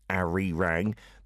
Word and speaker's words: Arirang: That is A-R-I-R-A-N-G. I know you'll see the romanized version and say Arirang, but it's allele in Korean, so it's Arirang for the correct pronunciation, just like the BTS album as well.